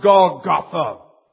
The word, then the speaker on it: Golgotha: In our English, it's pronounced Golgotha.